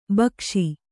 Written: ಭಾಗಸ

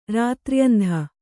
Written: ರಾತ್ರ್ಯಂಧ

